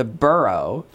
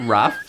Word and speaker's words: borough: And here they had seen a sign for the borough, for a local town, and it, they thought rough. It's spelled the same. Mm-hmm. You say rough, but here, borough.